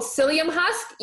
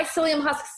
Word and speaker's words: psyllium: Okay, psyllium is spelled with a P.